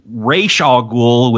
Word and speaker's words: Ra's al Ghul: But Ra's al Ghul, or I know some of our listeners are going crazy, so I'll just say Ra's al Ghul, which I think is how you're actually supposed to pronounce his name, but we're going to say Ra's because that's what they call him in the film.